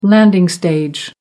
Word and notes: landing stage: American English

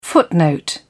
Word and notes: footnote: British English

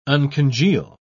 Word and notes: uncongeal: British English

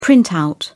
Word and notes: printout: British English

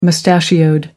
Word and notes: mustachioed: American English

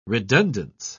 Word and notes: redundance: British English

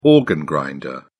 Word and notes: organ grinder: British English

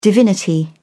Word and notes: divinity: British English

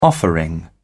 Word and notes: offering: British English

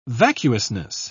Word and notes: vacuousness: British English

